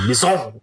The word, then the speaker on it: Besson: I don't know how you say his name. Luc Besson, I would assume. Yeah. – Besson. – Besson.